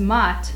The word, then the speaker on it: Maat: In Egypt, the goddess Maat, and that's spelled M-A-A-T. It's not like the applesauce. M-A-A-T.